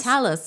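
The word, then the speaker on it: Talos: Yeah, because she saves him from the Talus. Is that how you say it? – Talus.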